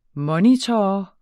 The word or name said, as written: MONITORER